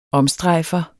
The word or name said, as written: omstrejfer